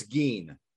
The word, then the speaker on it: Gein: I think it's Gein. If I'm not mistaken, I believe it's Gein.